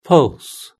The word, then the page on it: pulse: British English